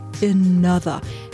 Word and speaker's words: ANOTR: Geschrieben A-N-O-T-R, ausgesprochen Another. Ist ein DJ-Duo aus Amsterdam, haben sich lustig geschrieben, heißen eigentlich Jesse oder Jesse van der Heijden und Oguzhan Güney.